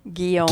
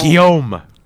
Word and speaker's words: Guillaume: Guillaume. – Guillaume.